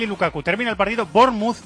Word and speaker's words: Bournemouth: Lo está ganando 0-2 al Bournemouth, que me ha dicho un oyente que se dice así, Bournemouth.